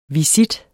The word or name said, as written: visit